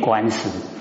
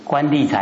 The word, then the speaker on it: 官吏: 哎，因为那个怎么念怎么念，我能讲讲，哎，历史为数，好啊，都会念官史，官吏才对了。